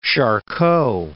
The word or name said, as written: Charcot